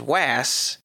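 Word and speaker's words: Was (Not Was): If you look at it, it's spelled was, not was, but actually spoken as was, with the first word rhyming with pass.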